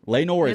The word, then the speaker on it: Laenor: Is it Laenor? – Yeah. Laenor is what we're going with. I don't know. Okay. It's spelled L-A-E-N-O-R. – Laenor. Why not? Laenor. Like Eeyore.